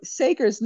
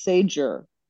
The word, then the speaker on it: Sager: Yeah, you know, Sager's name almost looks like it should be pronounced Sager. You know, yes. S-A-G-E-R, yes. But it's not. I agree. It's Sager.